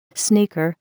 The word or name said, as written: sneaker